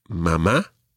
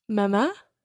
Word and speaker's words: mamá: But just so that you know, children are more likely to say mamá. – Mamá. – And papá. – Papá. – Now, just one other wee thing. We mentioned accents already. Mamá and papá both have accents on the last syllable.